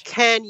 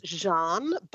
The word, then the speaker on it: Can: Can Yarman, Turkish actor, hot as hell. – Can Yarman. – Yeah. – Okay. – Actually, I think it's pronounced Jean, but it's spelt Can, C-A-N.